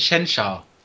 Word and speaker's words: Shehensha: Der indische Name war ausgesprochen Shensha. – Ah, okay. – Geschrieben Shehensha.